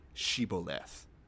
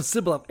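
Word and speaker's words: shibboleth: You make them say the word Shiboleth. And if they can't say it right because of the stupid-ass Ephraimite accent, we put them to the sword. – Yeah, they go Sibboleth.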